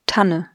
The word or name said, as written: Tanne